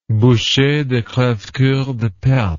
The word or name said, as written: Boucher de Crevecoeur de Perthes